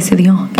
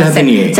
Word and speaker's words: Sevigny: You know, the one that just shopping all the time, the Chloe Sevigny. – Oh, yeah. – Loved her. She was my favorite. – Nikki. – Chloe Sevigny? I never know how Sevigny.